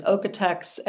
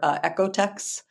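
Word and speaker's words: OEKO-TEX: What about yeah, I think the kind of American pronunciation is Okotex, and okay. Then sometimes in Europe you hear Ecotex. – Okay, okay. – But it's the O-E-K-O.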